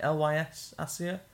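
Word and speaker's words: Lys Assia: Lys Asia, am I saying that right? Lys Asia. – Yeah.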